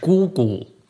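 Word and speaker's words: 姑姑: Das ist eine Tante und das heißt, das heißt auf Chinesisch Gugu. Gugu. Also Gu ist die Schwester deines Vaters und das hatte die gleiche Aussprache wie 孤单、孤独、einsam. – Ah.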